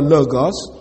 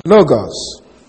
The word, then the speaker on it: logos: Now, the word saying is a translation of the Greek word logos. Logos. L-O-G-O-S. Logos, or logos, as some people pronounce it.